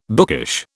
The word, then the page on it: bookish: British English